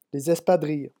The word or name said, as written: les espadrilles